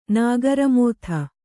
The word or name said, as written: ನಾಗರಮೋಥೆ